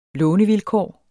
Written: lånevilkår

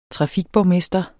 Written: trafikborgmester

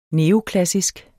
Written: neoklassisk

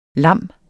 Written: lam

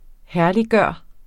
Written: herlig

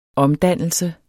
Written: omdannelse

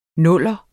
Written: nuller

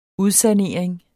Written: udsanering

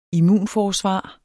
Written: IMMUNFORSVAR

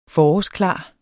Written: forårsklar

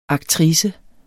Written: aktrice

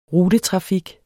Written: rutetrafik

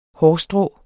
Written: hårstrå

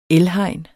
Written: elhegn

